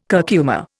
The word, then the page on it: curcuma: Indian